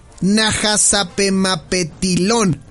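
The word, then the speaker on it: Nahasapeemapetilon: A ver si lo digo bien. Es Apu Najasapemapetilón. Ah, ¿lo dije bien? ¿Sí? ¿Lo dije bien? Bravo, lo dije bien.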